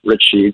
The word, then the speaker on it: Richey: So my social media handle across all the various platforms is Doug Richey. That's spelled R-I-C-H-E-Y.